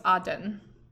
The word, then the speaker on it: Aden: I think it's pronounced Aden. I could be wrong. A-D-E-N.